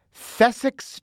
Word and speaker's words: Thessex: It's Thessex Johns. Thessex Johns. Thessex is spelled T-H-E-S-S-E-X.